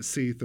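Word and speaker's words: sidhe: and some believe that they were, or the Seath or She, depending on the pronunciation used in Ireland, that they were the descendants of Tuatha De Danann and all of these different things.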